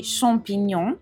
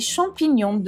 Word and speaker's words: champignon: Un'ultima nota di fonetica prima di abbandonare i funghi, non si dice champignon con la A aperta, ma il suono della A è molto più simile a quella di una O aperta, quindi champignon.